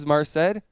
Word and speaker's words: Merced: Merced, California. – Merced. You've been saying it wrong the whole Merced. – Time. Merced. I told you it was Merced.